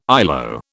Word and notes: ILO: British English

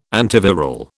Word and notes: antiviral: British English

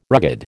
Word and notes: rugged: American English

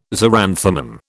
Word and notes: xeranthemum: British English

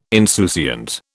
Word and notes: insouciant: British English

